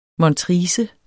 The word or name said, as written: montrice